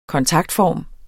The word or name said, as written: kontaktform